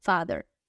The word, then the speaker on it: father: Father, father. La TH aquí suena como de, igual que la palabra mother. TH suena como de, Father and mother, ¿ok?